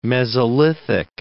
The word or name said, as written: Mesolithic